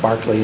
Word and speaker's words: Berkeley: But in the meantime, it's Barclay, and it's spelled like Berkeley, but it's pronounced Barclay.